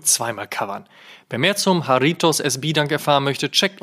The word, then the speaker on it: Jarritos: Der Haritos, fälschlicherweise auch von uns, Jaritos, wahlweise auch Haridos ausgesprochen, sorry dafür,